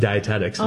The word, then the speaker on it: dietetics: Is it dietetics? Is that how you say it? – Yep, dietetics.